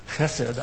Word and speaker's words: Chesed: Chesed, I can't pronounce that guttural very well.